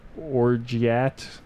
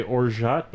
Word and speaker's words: orgeat: You know, I heard you say orgeat before, and I was like, oh, sounds like he knows what he's talking about. – I think it's orgeat, but I always forget that it's orgeat, and I say orgeat, so whatever.